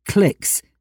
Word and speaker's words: Cliqz: It's spelt Qlix, C-L-I-Q-Z.